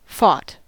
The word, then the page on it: fought: American English